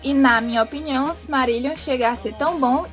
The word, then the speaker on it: Marillion: Os Marillion.